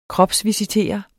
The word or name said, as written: kropsvisitere